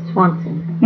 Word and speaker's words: Swanson: So your maiden name was Swanson. – And spell that for me. – S-W-A-N-S-O-N.